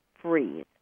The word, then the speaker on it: Fries: And that's why what Sue, and by the way, I mispronounced her last name. I said Fries. It's spelled F-R-I-E-S, but it's pronounced Freeze. So I wanted to correct myself on that. It's Sue Freeze.